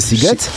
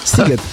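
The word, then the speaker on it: Sziget: że pisze się Siget, a wymawia się Siget? Siget.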